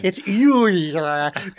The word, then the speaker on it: Euler: Fun fact about Euler, his name spelled real dumb, and that's not how you'd guess it's pronounced.